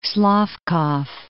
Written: Slavkov

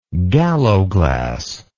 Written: galloglass

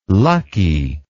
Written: luckie

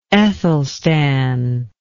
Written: Ethelstan

